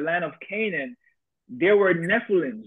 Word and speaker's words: Nephilim: In the book of Genesis, we know about the Nephilim, right? I believe that's what it's pronounced. – Yes.